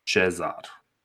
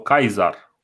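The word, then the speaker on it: Caesar: Uh, și acel om este Caius Iulius Cezar sau Caizar, dar nu o să-i spun. Îi zicem o singură dată așa ca să mi-o stoiesc așa pe, uh, dragostea de a pronunța lucrurile cum trebuie.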